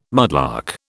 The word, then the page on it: mudlark: British English